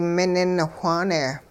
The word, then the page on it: minin whaane: hupa